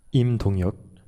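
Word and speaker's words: Lim Dong-hyek: Et Dong Yek Lim, oui, si je prononce bien. – Im Dong Yok, c'était très bien.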